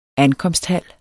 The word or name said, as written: ankomsthal